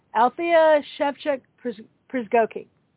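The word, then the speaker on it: Althea Szewczyk-Przygocki: Pronunciation for Al's name is Althea Shevchuk Przygocki, and it's spelled really Polish.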